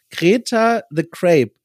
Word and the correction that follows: Greta the Grape: So, Greta the Grape, ich ja.